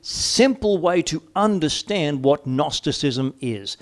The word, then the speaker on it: Gnosticism: If you just think of the phonetics, and it's spelled G-N, but the phonetics Gnosticism, think knowing. Those who followed and taught what we call Gnosticism, oh, it's knowing.